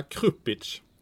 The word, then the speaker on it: Krupić: Vi har ju ett namn av samma, Amil Krupić. – Ja. – Är jag rätt på det? – Ja, Krupić. – Krupić.